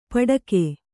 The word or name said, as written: ಪಡಕೆ